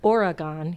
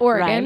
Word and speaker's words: Oregon: It's Oregon here. – Not Oregon.